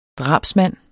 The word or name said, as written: DRABSMANDENS